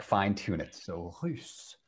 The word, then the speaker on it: russe: Russe. – Another pronunciation exercise, the U, it sounds like ou. It's like U. U. You want to make your mouth very small and say like U. Russe.